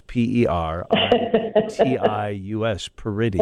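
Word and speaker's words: Peritius: Just like it sounds, Paritus, Paritus.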